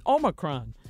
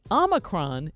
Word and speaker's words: Omicron: Now, there are two ways to say it. Now, when I learned it as part of the Greek alphabet, it's Omicron. But apparently, Omicron is also acceptable.